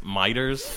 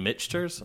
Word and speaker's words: Michter's: Like he knew what he was talking about because I ordered it as Miter's, and he was like, you mean Michter's? I was like, is that how you say it?